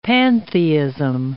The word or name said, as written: pantheism